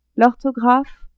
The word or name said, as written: orthographe